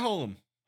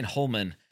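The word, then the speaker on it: Hullum: Holum, have I been saying his name wrong this whole time? – No, you got him. Matt Holum. – I was saying Holman.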